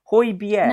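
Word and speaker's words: Højbjerg: Hoybier.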